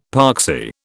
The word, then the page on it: poxy: British English